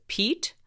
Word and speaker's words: Piet: Apparently, his first name is pronounced Piet.